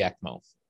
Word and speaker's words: ECMO: ECMO.